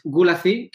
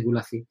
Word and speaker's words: Gulacy: Entonces, bueno, pues Gulacy, que se escribe así, Gulacy, G-U-L-A-C-Y, él dibujaba seguro esa etapa de Shang-Chi.